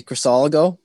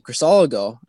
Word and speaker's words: Crisologo: So, myself and my family, we like to say Crisologo.